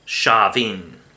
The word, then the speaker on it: Samhain: So it has nothing to do with Shavin, or Samhain as it's spelt.